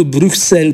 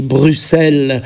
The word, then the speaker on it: Bruxelles: Eu m-aș mulțumi însă și cu un simplu Bruxelles pronunțat corect. Nu trebuie neapărat să graseiezi ca francezii Bruxelles și nici măcar să fluiere acel U cu buzele țuguiate, U, dar măcar accentul să cadă corect pe a doua silabă, adică nu Bruxelles, ci Bruxelles.